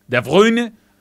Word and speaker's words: De Bruyne: De Bruyne.